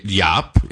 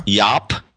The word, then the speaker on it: Jaap: He lives in Edinburgh, and his name is spelled J-A-A-P. – J Yop. Yeah. – Yop.